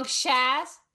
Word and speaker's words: Szasz: Szasz.